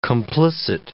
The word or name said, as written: complicit